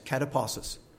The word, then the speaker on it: katapausis: It's a kind of a transcribed word, but K-A-T-A-P-A-U-S-I-S, kataposis.